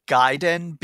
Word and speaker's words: Gaiden: I'm pretty sure it's Gaiden because Gaiden. – Yeah, because I believe it's like a Japanese word for a side story, and A is ah in Japanese pronunciation.